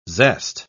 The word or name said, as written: zest